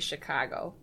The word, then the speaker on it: Chicago: It's you can really hear it when I say Chicago. Yeah. That's the correct way to say Chicago. And if you say it any other way, you're either not from Chicago or you're from the wrong part of Chicago.